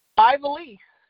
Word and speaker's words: Ivelisse: I'm going to do my best to spell that. I-V-E-L-I-S-S-E. Best way I pronounce it. – Yeah, that's okay. You're exactly right.